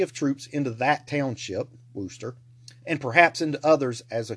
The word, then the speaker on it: Worcester: And it's spelled out Worcester, but I have an aunt and some family who live in Massachusetts, so I know that's pronounced Worcester.